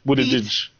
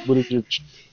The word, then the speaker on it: Buttigieg: Buttigieg. Buttigieg.